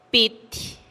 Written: pitih

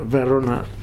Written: Varona

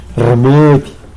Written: rmudy

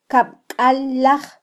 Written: kabkallaj

